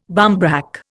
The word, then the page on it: barmbrack: Indian